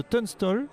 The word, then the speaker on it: Tunstall: Vous êtes le président de Tunstall.